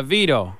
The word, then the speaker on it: Vito: Well, my name's Vito Cappuccino. How you doing, beautiful? – Vito? – Yeah, Vito.